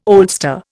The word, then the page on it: oldster: Indian